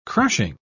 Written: crushing

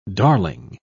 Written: darling

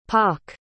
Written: pawk

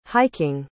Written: hiking

